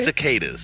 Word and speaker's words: cicadas: Is Skata? How do you say Cicadas. – It? Cicada? – It's a bug, dude.